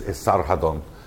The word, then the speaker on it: Esarhaddon: So the first successor to Sancheriv was, at the height of the Assyrian expansive empire, was Esarhaddon, pronounced, you know, he's got different pronunciations in Latin and in Greek and in Akkadian and whatever, but we know him basically as Esarhaddon.